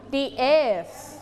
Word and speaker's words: eighth: เรามีตัวยกตัวนี้นะคะแปลว่าเลขตัวนี้เป็นเลขลำดับนั่นเองเวลาอ่านจะไม่อ่านว่า eighth เฉยๆแต่อ่านว่า the eighth นี้นะคะลิ้นต้องแตะหลังฟันบนปล่อยลมออกมานะคะเพราะว่าลงท้ายด้วย th นะคะ df next one what is it breakfast.